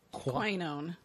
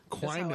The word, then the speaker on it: quinone: Quinone. – Quinone.